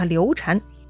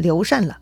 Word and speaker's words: 刘禅: 这个字啊是个多音字，一读禅，是佛教用语；另一个呢读禅，禅让的禅。虽然多少年的说书流传，大家都习惯叫他刘禅，但实际上啊，更讲究一点呢，就应该读成刘禅了。